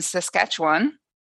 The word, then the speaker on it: Saskatchewan: And today we land in Canada, and more specifically in Saskatchewan, which I hope I am not butchering the name of.